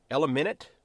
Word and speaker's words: Elliminyt: but I believe it's Elliminit, I believe is how it's pronounced in Victoria.